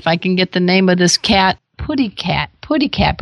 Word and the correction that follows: Putty Tat: Oh, it's actually Putty Tat. And oh, Putty Tat. – Yeah, that's the pronunciation from the cartoon.